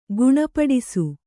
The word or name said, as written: ಗುಣಪಡಿಸು